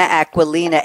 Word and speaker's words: Aquilina: Okay, and now let's bring on our third panelist, Anna Aquilina, A Q U I L I N A. I'm pronouncing it the way she asked me.